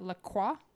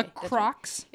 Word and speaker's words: La Croix: La Croix? – It's La Croix, isn't it? It's La Croix. – It's La Croix in America, but my dad is always quick to say, it's pronounced La Croix.